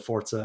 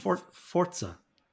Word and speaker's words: Forza: And then for the racing enthusiasts, there's the Forza and Forza Horizon games that, again, are, you know, reviewed particularly well, but I don't quite give a shit.